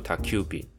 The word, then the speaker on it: 宅急便: 那刚才的宅急便叫做 Takubin，宅配便叫 Takuhaibin。那这个宅急便的 Takubin，这个宅 Taku 这个字在这里要转为促音，念作 Takubin。各位听友们，日后如果有机会要做商品广宣的时候，在用词上要稍作注意啊。宅急便 Takubin 是其他公司的专属商标。